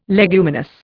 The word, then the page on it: leguminous: Indian